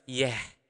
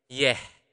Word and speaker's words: yeh: Water in Bahasa Bali is yeh, yeh. You maybe can heard that sound at the end. So h, right? So yeh is ended with a consonant h.